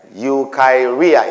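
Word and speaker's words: eukairia: Eukaryia. E-U-E like Edward. U-K-A-I-R-I-A. Eukaryia is pronounced eukaryia.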